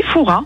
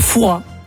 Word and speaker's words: Fouras: Eh bien écoutez, on dit Fouras. Ah ben, on ne prononce pas le S. – Et donc on dit bien Père Fouras.